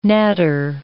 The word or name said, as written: natter